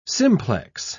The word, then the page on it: simplex: British English